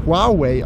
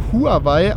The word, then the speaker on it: Huawei: Und da wir ja wissen, dass es international auf englischer Ebene auf jeden Fall Huawei ausgesprochen wird, haben wir hiermit den offiziellen Beweis, dass es vollkommen richtig ist, sowohl Huawei als auch Huawei zu sagen. Beide Versionen sind korrekt.